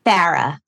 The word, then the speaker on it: Farah: Farah. – Farah, like a Michigan era.